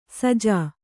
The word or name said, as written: ಸೋಜಿ